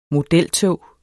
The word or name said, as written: modeltog